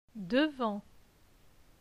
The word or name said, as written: Devant